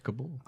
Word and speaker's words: Kabul: And it's Kabul.